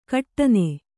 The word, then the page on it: ಕಠಿನ: kaṭhina